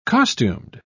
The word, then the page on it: costumed: British English